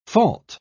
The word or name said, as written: fault